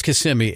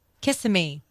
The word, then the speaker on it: Kissimmee: Well, it's Kissimmee, at least according to radio down there. – That's a good one. That's a good one. – Driving through Kissimmee, and I wasn't sure how to pronounce it, and I heard an ad on the radio.